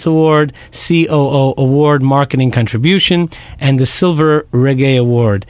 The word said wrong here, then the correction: Reggie: Hope I pronounced that correctly. – Reggie, I apologize. – It's the Reggie.